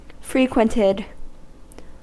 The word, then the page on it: frequented: American English